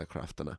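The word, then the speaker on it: Craiftine: That is spelled C-R-A-I-F-T-I-N-E, I think is how it's spelled. Yeah, Craftina.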